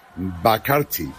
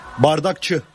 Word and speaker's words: Bardakçı: Bakarchi. – Bardakçı.